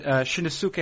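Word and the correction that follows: Shinnosuke: Abe. – Shinosuke. – Right. Shinosuke Abe, catcher.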